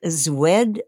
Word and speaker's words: Szwed: And Ken, I don't know if you know, she was not always Loretta Swit, S-W-I-T. – Mm-hmm. – Her original name was, and I don't think I pronounce it right, it was S-Z-W-E-D. She's Polish. – Yes. – And it was Zwed, I guess, is something like that.